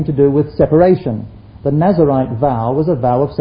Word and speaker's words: Nazirite: The person who lived in Nazareth was a Nazarene. This is Nazarite, not Nazarene, and it's not even spelt the same way. It's spelt N-A-Z-I-R-I-T-E, not N-A-Z-A-R.